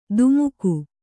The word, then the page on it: ಧುಮುಕು: Kannada